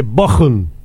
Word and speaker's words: Bochum: De vuelta Raúl desde Bochum, y me dice, bien pronunciado, con CH, entre dos vocales se pronuncia como J.